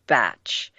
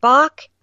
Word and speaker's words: Bach: And the fun part is that the English pronounce it Batch. So we call it Bach, they call it Batch.